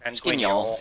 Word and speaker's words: Guignol: Is that how you say it? – Guignol. It's Guignol, but yeah.